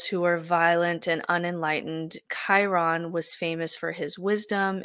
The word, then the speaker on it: Chiron: And so for this story, we're going to turn to Chiron, and it's spelled C-H-I-R-O-N. It's pronounced Chiron, who's a centaur.